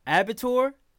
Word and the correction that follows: abattoir: Abattoir? Spell it. – A-B-A-T-T-O-I-R. – O-I-R. I don't know that. Abattoir.